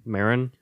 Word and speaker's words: Marin: Marin.